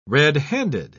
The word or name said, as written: red-handed